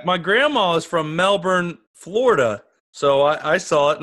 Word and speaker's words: Melbourne: And how do you pronounce it? Is it Melbourne or Melbourne? – It's Melbourne.